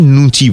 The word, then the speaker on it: লুচি: অনেক সময় এদেশীয় ঘটিরা লুচিকে নুচি বলে থাকেন